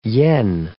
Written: yen

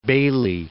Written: Baillie

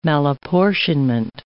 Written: malapportionment

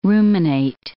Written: ruminate

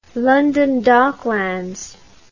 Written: london docklands

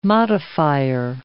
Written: modifier

